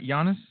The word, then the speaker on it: Giannis: So the Giannis and – am I saying that right, Giannis? – Yes.